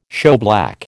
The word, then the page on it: shoeblack: American English